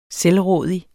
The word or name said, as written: selvrådig